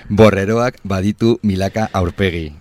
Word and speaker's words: Borreroak baditu milaka aurpegi: Borreroak baditu milaka aurpegi.